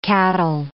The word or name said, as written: cattle